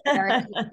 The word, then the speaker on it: Aire: Um, and I loved that his name, so she she said his name is pronounced as air and it's A-I-R-E and it's of Hebrew origin and it means um like strength of a lion, which right.